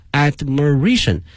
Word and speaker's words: admiration: Full of admiration for 雷祖 and her tribe，充满了羡慕啊，这里面这个词羡慕我们学过它的动词 admire， A-D-M-I-R-E 是个动词，而今天呢是一个名词啊，对一个人充满了羡慕呢是 full of admiration。同时啊同学们注意一下它的发音，从原来的 admire 变成了 admiration 啊，重音发生了变化。